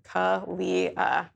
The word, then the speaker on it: Kalia: Because sometimes people look at Kalia and they're like, what? Kayla? So it's K-U-H-L-E-E-U-H on Twitter. So Kalia.